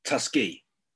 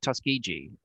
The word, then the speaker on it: Tuskegee: And, and I'd like you to explain to me and to the people listening about Tuskegee. That's the right way to say it. – Yeah, Tus- Tuskegee um, or Tuskegee.